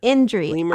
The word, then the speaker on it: Indri: How do you spell that again? Indri? – Indri. I-N-D-R-I or Babakoto.